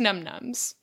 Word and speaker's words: Num Noms: Because it's pronounced num-nums, but It's spelled num-noms.